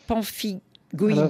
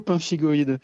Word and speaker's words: pemphigoïde: Le traitement pour la pemphigoïde bulleuse, je l'ai déjà bien prononcé, c'est déjà quelque chose. – Alors, on dit plutôt pemphigoïde.